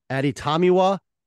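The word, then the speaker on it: Adetomiwa: So if you're looking for a semi, it's Tommy Adibare. That is how you pronounce it. Is Aditamiwa Adibare. That is how you say it.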